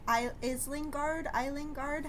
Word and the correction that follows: Isengard: How do you say that? Is that what it's called? – Isengard.